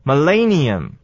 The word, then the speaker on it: millennium: 这个字啊，重音要摆在第二个音节，应该就念起来没什么大问题了，念作 millennium.